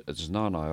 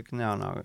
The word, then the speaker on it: jnana: Äh, J-N-A-N-A. – mina hääldaks eesti keeles Znana jooga. – venelased hääldavad Znanaks, aga siis ja. – Indias hääldatakse Gnana.